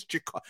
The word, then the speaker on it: Ja'Kari: I know it's spelled that way, but guys, I promise you, his name is pronounced Ja'Curry.